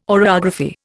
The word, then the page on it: oreography: Indian